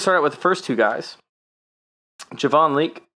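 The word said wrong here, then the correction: Leake: is that how you say it? And McFarland out of Maryland. – Anthony McFarland? – Is that Anthony McFarland, yeah. And Javon Leak. Javon Lake and Anthony McFarland, that's how you say the name of Javon.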